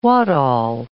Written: what all

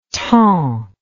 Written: temps

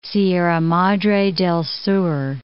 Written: Sierra Madre del Sur

